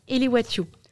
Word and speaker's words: élévation: Le savais-tu que ce mot d'origine latine signifiant action d'élever est apparu au XIIIe siècle Eh bien oui, à l'époque celui-ci se prononçait elewatio. Aujourd'hui il se prononce élévation dû à la différence de sonorité, comme c'est le cas par exemple pour la lettre V qui est devenue une sonorité consonantique fricative.